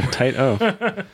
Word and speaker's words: Taito: It's Taito. – So we'll see. Taito. – It's Taito. – Taito. – Yeah, like that. Yeah, Taito.